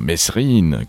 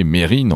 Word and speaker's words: Mesrine: Si l'on a plutôt tendance à dire Mesrine, comme dans la chanson de Trust, c'est une erreur. Roger Knobelspies, qui a côtoyé Mérine en QHS, quartier de haute sécurité, nous indique que le S ne se prononce pas.